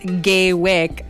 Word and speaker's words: Gaywyck: Gay Wick by Vincent Verga. – Gay Wick spelled G-A-Y-W-Y-C-K.